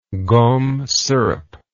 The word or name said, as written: gomme syrup